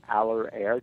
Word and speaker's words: Allerair: Aller Air, that's spelled Allerair Industries.